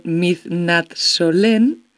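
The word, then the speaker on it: Midnattssolen: a ver si lo digo bien, Midnatt Solen, se dice así, sí.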